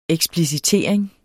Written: eksplicitering

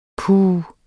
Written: puh

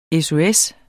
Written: SOS

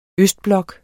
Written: østblok

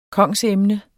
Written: kongsemne

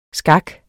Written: skak

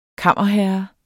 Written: kammerherre

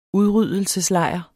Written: udryddelseslejr

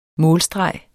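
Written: målstregen

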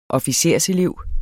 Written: officerselev

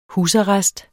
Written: husarrest

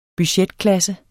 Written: budgetklasse

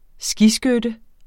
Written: skiskytte

